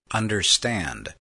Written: understand

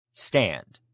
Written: stand

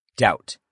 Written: doubt

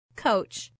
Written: coach

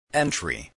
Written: entry

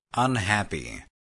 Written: unhappy

